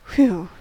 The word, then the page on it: whew: American English